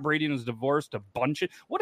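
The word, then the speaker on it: Bündchen: I think it's Bunchin. – Bunchin. – That's how you say that? – Yeah, Bunchin. – Oh, silent D.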